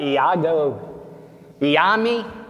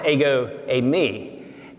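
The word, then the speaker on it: ego eimi (ἐγώ εἰμι): Iago Iami? Well, it's pronounced ego eimi.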